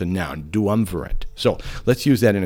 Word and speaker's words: duumvirate: Dumerative. I'm screwing it up. It's such a unique word. Duumvirate. That's it. Duumvirate. You try to spell it. You try to do it. It's D-U-U-M-V. I-R-A-T-E, duumvirate.